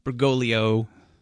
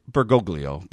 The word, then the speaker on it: Bergoglio: Second question is, read the statement that was made at the LCMS website at the election of Cardinal Bergoglio, I think is how it's pronounced, as Francis. – It's actually Bergoglio.